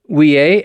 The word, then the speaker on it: Ouellet: Last weekend, Cardinal, how do you say that? Ouellet, O-U-E-L-L-E-T? – Yeah, Ouellet.